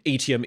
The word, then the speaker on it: Atium: And so ATM is how it's pronounced in the audiobooks.